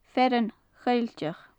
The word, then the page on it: fearann choillteach: gaelic